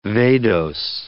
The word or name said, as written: vadose